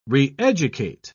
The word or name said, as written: reeducate